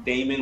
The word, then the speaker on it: Damon: I'm at The Shark Damon. My name is spelled Damon, like Raymond with a D.